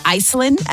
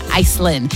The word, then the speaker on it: Icelynn: Iceland as well, okay, but spelled like I-C-E-L-Y-N-N. Yeah, Iceland.